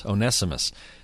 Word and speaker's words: Onesimus: Or Paul writing to Philemon about, what is his name, Onesimus? How do you pronounce it? – Onesimus.